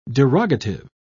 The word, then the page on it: derogative: British English